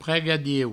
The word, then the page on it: pregadieu: Provençal; prego-dieu